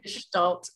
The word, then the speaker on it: Gestalt: How do you say it? Gestalt? – Equine Gestalt coaching. Equine Gestalt. Yeah. German word.